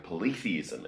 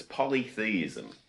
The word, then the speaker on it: polytheism: First thing I want to say is I've been pronouncing it wrong a lot of the time. I've been saying polytheism, and it's polytheism.